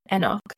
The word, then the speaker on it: Enoch: Some people call it Saint Enoch. I heard Enoch. So it just depends how you pronounce it. But the angel who probably knows best said Enoch. And they showed me how to spell E-N-O-C-H.